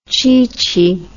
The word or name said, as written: cheechee